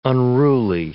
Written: unruly

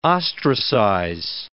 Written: ostracize

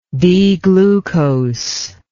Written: D-glucose

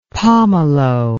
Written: pommelo

